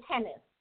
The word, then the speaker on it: Hennes: It's Hennes. – Hennes. Okay, I was in Hines. Sorry, it's Hennes. Hennes. – That's okay. – And it's spelled okay. – H-E-N-N-E-S.